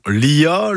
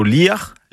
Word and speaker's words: Lia: On le retrouve dans Lia, le Lia, Liaven, Kerlia, Leslia et d'autres formes aussi avec une prononciation légèrement différente qui serait plutôt Liar, Liarven, Kerliar.